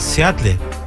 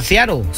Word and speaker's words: Seattle: O sea, Seattle. – Se escribe Seattle. ¿Cómo Seattle, se Washington. Pronuncia? – Seattle.